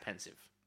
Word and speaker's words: Pensieve: What is it? Pensieve? – Yeah. – It's a silly name. – Yeah. Should be a pensive. – I mean, it's pronounced pensive.